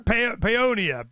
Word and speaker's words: Paonia: Beloved for her humorous, non-judgmental, get her done style, Marjorie raised two teenagers in Central Texas and currently splits her time between Paonia, Colorado. Did I say it right? – Yep. – Paonia, well, that was a guess.